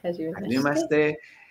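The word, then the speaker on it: Hajimemashite (はじめまして): Hajimemashite.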